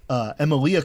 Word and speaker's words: Emilia: And Emilia Clarke. – Daenerys is in it. – Did I miss that? Sorry. – I blanked on her first name, mostly because it's Emilia Clarke, I believe, right? – Yeah.